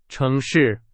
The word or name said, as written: 城市